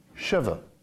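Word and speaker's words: Sheva (שבע): Sheva.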